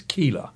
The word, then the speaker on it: Keilah: Now, in the King James, and the King James pronunciation is Keilah. – Hmm. – And so... – K-E-I-L-A-H.